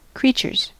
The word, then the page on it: creatures: American English